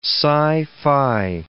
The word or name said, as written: sci-fi